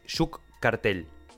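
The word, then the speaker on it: Juke Cartel: Yuk Cartel. Ok, ¿cómo se escribe? Se escribe J-U-K-E. Sí. Cartel como suena, pero con K en vez de con C.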